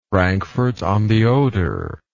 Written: Frankfort on the Oder

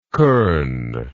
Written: kirn

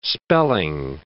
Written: spelling

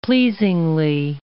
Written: pleasingly

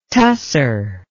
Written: tussur

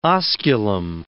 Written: osculum

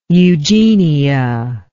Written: eugenia

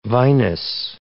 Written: vinous

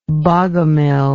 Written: bogomilianism

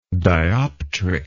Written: dioptrically

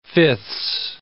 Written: fifths

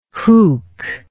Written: huk

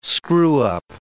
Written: screwup

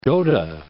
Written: Gotha

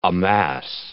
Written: amass

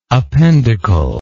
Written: appendicle

